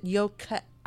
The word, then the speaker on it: Yokai: Yokai, is that how am I pronouncing this right? Yokai, is it Yokai. – Yokai. – Okay, thank you. – You did say it right.